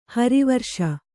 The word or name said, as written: ಹರಿವರುಷ